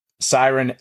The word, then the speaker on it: Syron: So Siren, S-Y-R-O-N, but pronounced like siren, like on the cop cars kind of thing, right? – Yeah, that's right.